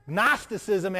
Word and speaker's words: Gnosticism: If you ever wondered why the word know, K-N-O-W, is spelt like that, it's because the Greek word is spelled G-N-O. Gnosticism has a silent G in front of it.